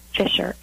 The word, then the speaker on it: Fisher: Fisher, F-I-S-H-E-R.